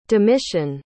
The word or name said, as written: domitian